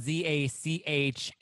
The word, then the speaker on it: Zach: Well, my uh, so my name is Zachary, so it's spelled Z A C H A R Y, the, oh.